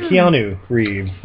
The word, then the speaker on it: Keanu Reeves: I'm sorry, Keanu Reeves. That's how you pronounce it.